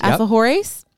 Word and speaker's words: alfajores: alfajores.